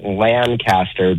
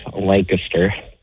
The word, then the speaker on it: Lancaster: And I know you Californians say it Lancaster, but it's actually pronounced Lancaster.